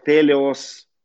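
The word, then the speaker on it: телиос (τέλειος): Слово «совершенным» переводится на греческом, читается как «телиос», «телиос». Это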